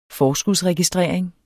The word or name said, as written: forskudsregistrering